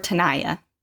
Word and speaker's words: Tenaya: T-E-N-A-Y-A. – Tenaya? – Or Tenaya.